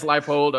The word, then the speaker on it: Leipold: I want to talk about Lance Leipold. You know, did I get his name right? – Yep, that's right. – Lance Leipold a little bit in this program,